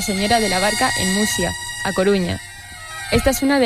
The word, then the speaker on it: Muxía: Es Muxía. – Muxía, vale. No, pero entendemos el error y Fer más que nadie entiende el error. – Sí, porque yo estuve mucho tiempo con lo de la Muxía esta cagándola.